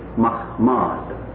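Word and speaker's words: Mahmad (מחמד): When pronounced correctly, with the inserted vowel points in the Hebrew text underlying the King James Version, it's Mahmad.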